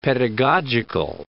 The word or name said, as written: pedagogical